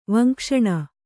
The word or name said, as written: ವಂಕ್ಷಣ